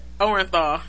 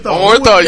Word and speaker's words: Orenthal: Who would have guessed Orenthal? Am I saying it right? – Orenthal. – Yeah, Orenthal. You said it right.